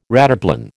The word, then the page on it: rataplan: American English